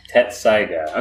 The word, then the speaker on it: Tetsusaiga: Now, you say it correctly. – Tetsaiga. – Okay, so the middle part is a little silent, or at the very least imperceptible with the rest of the word.